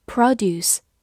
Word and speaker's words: produce: 此外，它还可以做一个名词，但这个时候它的重音放在了最前面，读作 produce。 produce，美式发音 produce。